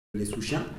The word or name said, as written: souchiens